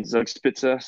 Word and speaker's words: Zugspitze: Oh yeah, we had the highest peak in all of Germany is there in Zugspitze.